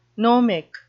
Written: gnomic

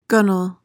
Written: gunwale

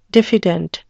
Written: diffident

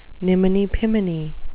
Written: niminy-piminy

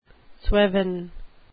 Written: sweven

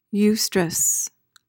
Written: eustress

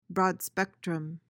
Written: broad-spectrum

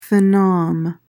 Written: phenom

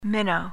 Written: minnow

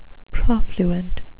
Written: profluent

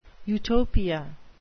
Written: utopia

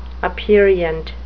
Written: aperient

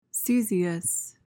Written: caesious

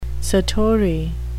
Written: satori

